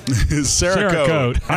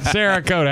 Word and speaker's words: Cerakote: Cerakote. – I got it. Cerakote. I had to think. – Cerakote. Like Cerakote. Woman's name. And I'm going to spell it S-A-R-A.